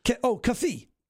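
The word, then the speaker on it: keffiyeh: Kafi. – Kafi. – There it is. It's kafi. It's K-E-F-F-I-Y-E-H, a kafi, which is the same thing you see the Palestinians wear.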